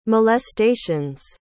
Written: molestations

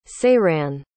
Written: saeran

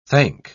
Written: thank

